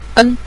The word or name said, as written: en5